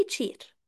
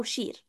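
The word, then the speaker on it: chair: النطق الصح ان انا انطق الكلمة صح، يعني ما اقولش مثلا مثلا ما اقولش على التشير مثلا هو كرسي تشير او شير او كده، انا بقول اي تشير بغض النظر الاكسنت ايه، هم في كل الاكسنتس بينطقوه كده تشير،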